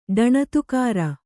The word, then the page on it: ಢಣತ್ಕಾರ: ḍhaṇatkāra